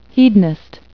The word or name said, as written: hedonist